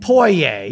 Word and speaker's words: Poirier: My Crown Royal Athlete of the Week, Dustin Poirier.